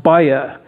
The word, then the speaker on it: Baja: It's spelled exactly like Baja, but it's pronounced Baja, and it means trouble.